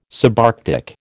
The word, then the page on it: subarctic: American English